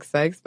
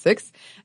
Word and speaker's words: syx: It looks like syx, but it is probably pronounced six.